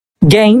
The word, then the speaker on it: Gein: Então, eu vou conversar com vocês sobre o Ed Gein, eu não sei se fala gay ou Gen. A, a, que é ficcionada nessas coisas, vai saber falar. Como pronuncia o sobrenome desse cara, doido? – Amigo, eu pronunciei Ed Gain. Gain mesmo. É.